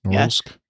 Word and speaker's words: Norilsk: I pronounced that incorrectly. I don't know. Anybody have any idea? You ever flown in Norilsk? – Norilsk. – Yeah. Yeah, Norilsk is good.